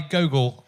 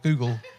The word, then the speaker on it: Gogol: You said you were doing a PhD on Nikolai Gogol. Google. I wouldn't have done very well doing that. I'd have been caught straight away. Because they'd have gone, why are you saying his name wrong? – I never said his name like that.